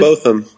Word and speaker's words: Botham: Botham? I don't feel like I'm ever saying his name right. – Yeah, what Botham. – Botham. – Yeah, he went by Bo, actually, the people here in Dallas. So, yeah, Botham.